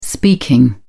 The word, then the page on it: speaking: American English